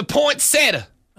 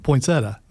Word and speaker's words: poinsettia: It's a poinsettia. – That's how I've heard it said, without the accent, poinsettia, not the Sieta thing.